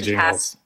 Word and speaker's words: Gingles: I mean, maybe like a hard G. I don't no. – know. Jingles versus Thornburg. – It's definitely Jingles.